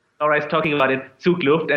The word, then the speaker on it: Zugluft: Zugluft.